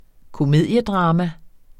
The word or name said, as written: komediedrama